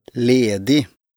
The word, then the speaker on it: ledig: Betont wird die erste Silbe. – Ledig.